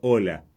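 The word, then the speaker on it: Olah: Bueno, fue invitado el cofundador de Anthropic, que se llama, como comenté anteriormente, Christopher Ola, así como se escribe, la ola del mar, pero con una H al final.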